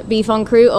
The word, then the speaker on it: en croûte: Help me pronounce that. – Beef en croûte. – Beef en croûte. – There you go. – Like beef en croûte. In French, yeah.